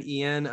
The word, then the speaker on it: Eion: Oh, I call my friend Ian. Something I've started calling him is Yoni. – What's that? – Because his name is spelled weird. So his name is spelled E-I-O-N.